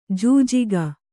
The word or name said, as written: ಜೂಜಿಗ